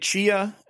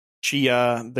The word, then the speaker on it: Tchia: I don't know if we want to do all of them or just kind of, pick between the two, but I played Tchia and I played Have a Nice Death. I think Tchia is probably the more interesting game just because it's going for something really different.